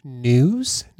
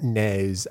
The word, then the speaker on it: news: Okay, one more bit of news and then we can get into, by the way, it's pronounced news, not nays.